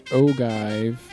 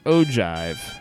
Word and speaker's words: ogive: Says here, the pronunciation is ogive. – Ogive. – Huh. Ogive.